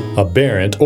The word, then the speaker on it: aberrant: Aberrant, or aberrant, depending on how you want to pronounce it. A-B-E-R-R-A-N-T, aberrant. A-B-E-R-R-A-N-T, it's an adjective.